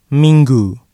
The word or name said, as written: minggu